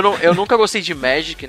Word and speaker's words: Magic: Eu, go eu não gosto de Magic. Desculpa, é, eu pronunciei errado. É Magic.